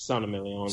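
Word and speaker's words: Saint-Émilion: Is it Saint Emilion? Is that how you say it? – It's Saint Emilion.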